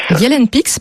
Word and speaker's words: Yelnepix: Donc, ça s'appelle Yelnepix, c'est comme ça qu'il faut prononcer ? – Yelnepix, pardon.